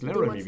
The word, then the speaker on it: Laramie: Die haben für den Vertrag von Fort Laramie, wird wahrscheinlich auch anders ausgesprochen, das ist Laramie, wie die Zigaretten.